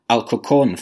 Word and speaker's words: Alcorcón: But as well as Cork City and Arsenal, as we said at the start, you're an Alcorcón fan. And, you know, tell me if I'm saying it wrong, because you've corrected me just before we started recording. – You're saying it well, man.